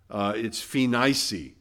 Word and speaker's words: Phenice: So they went through, and this is pronounced differently than we would by how it's spelled, but it's phenice. Okay, all long E.